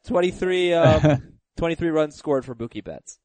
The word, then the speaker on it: Mookie Betts: Is it Mookie Betts? – His name rhymes with Kookie Metz. Yes, yeah. – Mookie Betts.